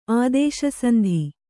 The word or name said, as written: ಆದೇಶಸಂಧಿ